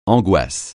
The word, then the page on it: angoisse: francais